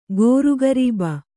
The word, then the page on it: ಗೋರುಗರೀಬ: Kannada